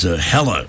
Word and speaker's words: Hela: who is Hela in Thor Ragnarok. Did I pronounce that right? – Yeah.